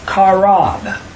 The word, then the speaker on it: qarab: Now the verb, so korban is a noun, and the verb which comes from is karab, like K-A-W-R-A-B. It's spelled Q-A-R-A-B.